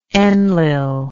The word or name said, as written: Enlil